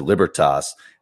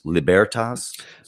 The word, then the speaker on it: Libertas: I said Libertas and it's Libertas.